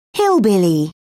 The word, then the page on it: hillbilly: British English